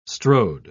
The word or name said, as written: strode